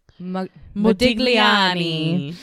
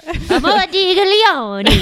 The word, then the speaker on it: Modigliani: He met Sonia and Robert in Paris, where he was living, studying, and having success alongside guys like Brock and Modigliani. – You have to say it like that. – Modigliani.